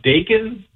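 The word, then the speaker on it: Daikin: It's D-A-I-K-I-N. I think it's pronounced Dakin.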